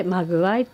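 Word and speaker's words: 目合: 目と目を合うと書いて間具合って読む、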